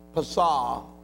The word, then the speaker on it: Pasah: Pasah, P-A-S-A-H, but the H in the Hebrew is silent, so it is Pasah.